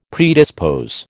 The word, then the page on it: predispose: American English